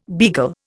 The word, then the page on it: beagle: Indian